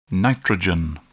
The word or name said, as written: nitrogen